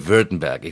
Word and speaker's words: Württemberg: It is Württemberg. It looks like Württemberg when you look at it, but it's a Württemberg. – It's Württemberg, exactly. Very, very well pronounced.